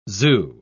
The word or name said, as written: zoo